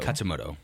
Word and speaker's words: Katsumoto: We're introduced to Katsumoro. Is it Katsumoro or Katsumoto? Katsumoto. Okay, I've been saying it wrong this whole time.